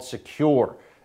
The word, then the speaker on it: Sekur: That's why you need a new privacy and security outfit called Sekur.